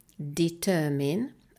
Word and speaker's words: determine: Kiejtése determine, a hangsúly a második szótagon van, jelentése pedig meghatározni.